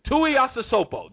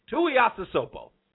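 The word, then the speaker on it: Tuiasosopo: and Renee Tai Supioso, whatever his name is, you'll hear the name. I can't pronounce it right now. Tui Asasopo, that's it, Tui Asasopo.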